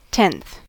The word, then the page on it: tenth: American English